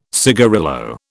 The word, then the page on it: cigarillo: British English